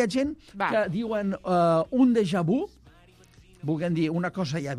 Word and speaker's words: déjà vu: Déjà vu. Déjà vu. Vu. Amb una V labiodental i amb una I labialitzada. És a dir, perquè la U sona I labialitzada en francès.